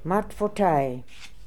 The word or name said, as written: Martfotai